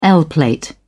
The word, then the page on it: l plate: British English